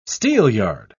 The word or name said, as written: steelyard